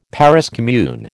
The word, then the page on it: Paris commune: American English